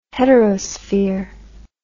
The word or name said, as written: heterosphere